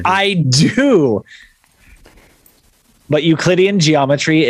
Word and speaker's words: Euclidean: When Einstein was 12, he taught himself algebra and, I'm going to butcher this name, Euclidean geometry.